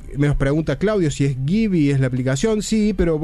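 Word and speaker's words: Giby: Se escribe G de Guillermo, I de Indio, B corta de Víctor, Y de Yankee. Givi se pronuncia, pero se escribe así, Givi.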